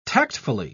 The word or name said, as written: tactfully